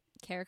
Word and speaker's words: Ceara: Yeah, and Kara Kirkpatrick here. That's so funny that you said that, because I also have a very unique name. My name is spelled the Irish way, so it's C-E-A-R-A, Kirkpatrick.